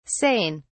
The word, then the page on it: sain: British English